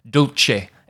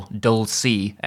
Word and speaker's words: dulce: So beginning with the title, I'm pronouncing it in the Italianate fashion that was used in Roman Catholic churches in Owen's day, dulce et decorum est. But you may hear it pronounced elsewhere as dulce or dulci et decorum est, depending on what kind of Latin is being used.